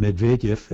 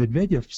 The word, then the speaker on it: Medvedev: Well, it's interesting that Obama and Medvedev, if I can correct your pronunciation, Ellie. Well, why didn't you take Russian? My wife taught it. Obama and Medvedev seem to have a particularly good relationship, not just politically, but personally.